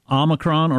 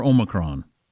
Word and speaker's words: Omicron: And it says here that Omicron is pronounced, well, it's got two pronunciations, so I guess that means you can do it either way. Omicron or Omicron. So you can do either one.